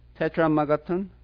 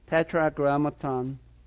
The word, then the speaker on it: Tetragrammaton: Tetramagaton, Grammagaton. Tetragrammaton. Yeah. It's not something you use every day. Tetragrammaton.